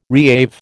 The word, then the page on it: reave: American English